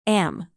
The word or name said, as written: am